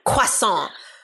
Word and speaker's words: croissant: She said it like that. Croissant. I don't pronounce things actually in the native tongue, even though I know very well that the French call it a croissant, but in New York, it's a croissant. I was really impressed that she was sticking to the French, the French pronunciation.